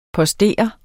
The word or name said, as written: postere